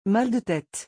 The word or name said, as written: mal de tete